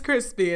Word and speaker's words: Chrispy: And my name is Crispy, and that's spelled C-H-R-I-S-P.